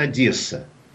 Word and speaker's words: Одесса: Дело в том, что по-русски говорят Одесса. Ну, вот русское произношение. А по-украински Одесса.